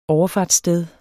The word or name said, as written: overfartssted